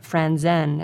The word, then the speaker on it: Franzen: And the great Swedish-American man of letters, Jonathan Franzen, or Franzen, as the Swedes pronounce it, drops down to number 10 with Freedom in his eighth week.